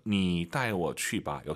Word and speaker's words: 你带我去吧: 你带我去吧. 역시 니 올라가면 안 돼요. 뒤에가 사성이니까 반삼성. 你带我去吧.